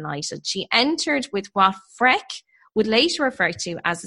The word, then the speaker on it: Freke: It's spelled F-R-E-K-E. So I'm saying freak, but it sounds so wrong. – Freck. – Freck? Let's go Freck.